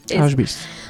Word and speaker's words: Auschwitz: Auschwitz.